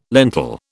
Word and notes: lentil: British English

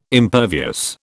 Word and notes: impervious: British English

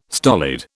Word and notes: stolid: British English

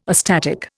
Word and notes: astatic: Indian

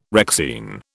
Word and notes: Rexine: British English

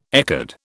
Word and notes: ecad: British English

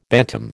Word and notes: bantam: American English

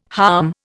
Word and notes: halm: Indian

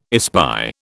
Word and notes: espy: British English

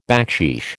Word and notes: backsheesh: American English

